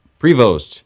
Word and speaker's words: Prevost: Prevost.